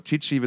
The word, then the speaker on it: Tichy: Brent Woods, Zach Tron, der auch bei Corey Taylor schon mal gespielt hat und oder immer noch spielt in der Band, Brian Tichy, oder Tichy wird es ausgesprochen, der bei Lynchmob, der Daisy's Whitesnake, Billy Idol und bei allen gespielt hat, die nicht bei drei auf dem Baum waren irgendwie.